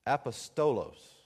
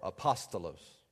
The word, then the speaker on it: apostolos: But when you look at the word apostle in the Bible, it's apostolos or apostolos is really the correct pronunciation.